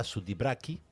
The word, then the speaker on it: Sudbrack: El bar de Burka es un bar tradicional brasileño que me recomendó mi amiga Roberta Sudbrack, que se llama, en realidad se pronuncia Roberta Sudibraki. Me encanta.